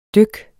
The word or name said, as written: dyk